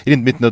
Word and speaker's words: Ирина Дмитриевна: Ирина Дмитриевна, добрый вечер. – Добрый вечер. – Все правильно сказал? – Все правильно, абсолютно.